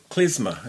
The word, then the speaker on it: klezmer: Going back to the music, another project that you've had is the Kugels, who play the klezmer. Is that how you pronounce it? – Klezmer, it is indeed, yeah.